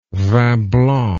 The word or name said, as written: vin blanc